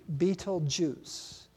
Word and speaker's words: Betelgeuse: It's not like the old movie. It's spelled way different. Betelgeuse. Betelgeuse is how it's pronounced.